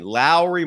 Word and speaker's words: Lauri: I cannot get that out of my head, man. That is, you blew my mind with that pronunciation. Lauri Markkanen.